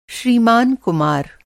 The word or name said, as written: श्रीमान